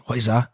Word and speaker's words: Heuser: But what we'll do is we'll get into the first one, which is Claudia Heuser, which I had to make sure I said her name right, and it's with like a Z. – Silent Z. – Yeah, Heuser.